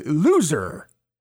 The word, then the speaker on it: Zher: It's spelled L-O-U, and the last name is spelled Z-H-E-R. But when you pronounce it, it's basically loser.